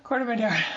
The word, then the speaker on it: Corte Madera: Corte Madera.